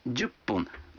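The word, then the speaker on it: джупун (十分): А 10 минут – джупун. Вы заметили, что когда фун добавляется к 10, дю, то произносится пун.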